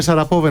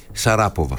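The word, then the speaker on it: Sharapova: Η Μαρία Σαραπόβα είναι αυτή που σας έλεγα προηγουμένως, στην ταινίστρια που δεν είχε φίλες. – Γιατί Πόβα και όχι Σαράπόβα? – Τι να κάνω, έτσι την προφέρουν εκεί πέρα.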